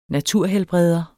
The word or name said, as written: naturhelbreder